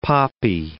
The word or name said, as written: poppy